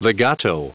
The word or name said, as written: legato